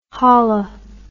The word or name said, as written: holla